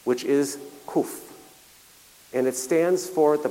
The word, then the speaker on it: qof: That 100 mean is the Hebrew letter kuf, Q-O-F. Some people pronounce it kof, which simply means 100.